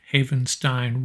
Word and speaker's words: Havenstein: Number 17, our own Rob Havenstein, rhymes with Frankenstein.